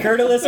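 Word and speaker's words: Kurtulus: It's K-U-R-T-U-L-U-S. – It's Curtulus.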